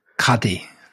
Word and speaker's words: Qadi: Is it a Qadi? – Yeah. – You sure? – Yeah. – You're not bluffing? – Qadi. – Qadi. So His this guy, he had been named as an Islamic judge, as a Qadi, by the Sultan. – Very good. – That was beautiful Arabic pronunciation.